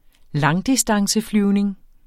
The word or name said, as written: langdistanceflyvning